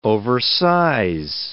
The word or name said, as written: oversize